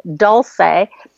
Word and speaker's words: Dolce: And we're all really, really sad because Dulce, which is spelled D-O-L-C-E, like the Italian word for sweet, it was actually pronounced like the Spanish word for sweet, and as in dulce, because Heather didn't want to have her dog be thought of like a brand, you know, like Dolce Gabbana.